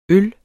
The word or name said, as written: øl